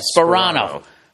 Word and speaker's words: Sparano: It's Tony Spir- Sparano? Yeah, Sparano. Tony Sparano. – The, the, the P's in the beginning. Oh, okay. Yeah, it's not Soprano.